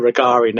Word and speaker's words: Rogeri: And you can easily remember how to pronounce it. It's like Volare. So whenever you think of Rogeri now, you'll just think of whoa, whoa, whoa, whoa at the end of it.